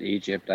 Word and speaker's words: Ægypt: Do you pronounce it Egypt, or do you pronounce it? – I pronounce it Egypt.